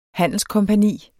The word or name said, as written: handelskompagni